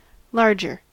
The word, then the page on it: larger: American English